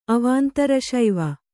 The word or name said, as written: ಅವಾಂತರಶೈವ